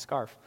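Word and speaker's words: Scharff: I kept trying to say scarf to make it sound more German, but I looked it up. It's pronounced scarf.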